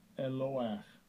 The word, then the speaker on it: Eloah: Strong's H430, entry 1. Eloah. Eloah. Second entry. Elohim. Elohim. And the third entry. Eloah. Eloah.